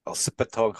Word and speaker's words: Elsipogtog: Elsipogtog Hawks.